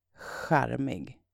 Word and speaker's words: charmig: It's spelled C-H-A-R-M-I-G. And also pronounced with that kh sound in the beginning. Charmig. Charmig. Can you imagine learning how to spell in Swedish?